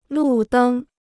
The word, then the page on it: 路灯: lù dēng